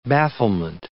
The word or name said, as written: bafflement